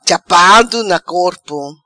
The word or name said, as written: Chapado Na Corpo